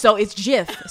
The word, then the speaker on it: GIF: It's Jif. So it is Jif. The creator of Jif said that it's pronounced Jif, like the peanut butter Jif.